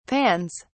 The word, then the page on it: pans: British English